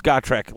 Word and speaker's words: Gotrek: Actually heard it pronounced by the guy who wrote the books, and if you listen to the audiobooks, it's Gotrek. Like, that's the pronunciation.